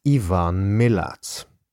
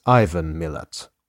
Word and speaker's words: Milat: In the first episode of this series, I started out by pronouncing the killer's name as Ivan Milat. I should, of course, have been pronouncing his name as Ivan Milat all along.